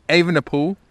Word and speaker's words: Evenepoel: So the five or four that came in together were Evenepoel is how I now pronounce it.